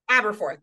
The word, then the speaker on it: Aberforth: Aberforth, not Aberforth.